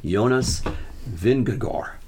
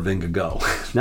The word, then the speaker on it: Vingegaard: The correct pronunciation is Jonas Vingegaard, not Vingegaard or Vingego.